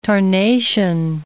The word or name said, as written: tarnation